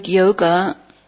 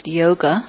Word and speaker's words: yoga: The word yoga is of course a Sanskrit word and it's not pronounced yoga it's pronounced yog and it means the connection.